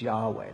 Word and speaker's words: Yahweh: And in this form, this is spelled in Hebrew with consonants, YHWH. Because they don't put the vowels in when they spell words. But it's Yahweh.